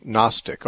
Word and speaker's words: Gnostic: First of all, the word Gnostic has a G at the beginning, but it's usually not pronounced. So it, rather than Gnostic, it would just be Gnostic, although there is a G there.